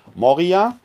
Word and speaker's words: Moria: Denn dort gibt es auch ein Moria, wird anders geschrieben und anders ausgesprochen. Die, die, äh, der griechische Ort heißt mit offenem O, mit Omikron geschrieben Moria. Wo ich spreche jetzt von Moria, das ist der Berg.